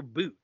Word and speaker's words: Bout: They recently changed his name from Victor Bout to Victor Boot. It's like the pronunciation change.